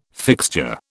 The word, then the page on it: fixture: British English